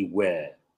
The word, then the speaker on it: were: Y el were se pronuncia con un sonido A. Un R.